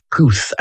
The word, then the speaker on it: Puth: P-U-T-H is the spelling of his surname. It's pronounced Puth, as in P-O-O-T-H. Puth.